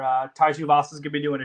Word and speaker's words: Tuivasa: Tai Tuivasa.